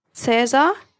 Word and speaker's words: Cäsar: So Anton, Bertha, Cäsar. – Wait, is that a name? – No, it's not a name. It just means Caesar. It's a German pronunciation.